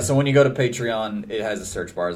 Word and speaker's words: Patreon: Emphasis on the E-O-N. It's not Patreon. It's Patreon. And then when you donate, you're called a patron.